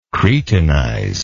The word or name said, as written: cretinize